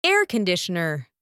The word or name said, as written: air conditioner